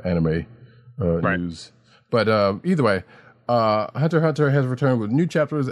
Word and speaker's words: Hunter x Hunter: Hunter x Hunter artist remembers late Yu-Gi-Oh! Creator in special tribute. And yes, I say it like that. Come at me. I know it's Hunter Hunter.